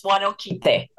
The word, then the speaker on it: Huanoquite: Um, it's Huanoquite.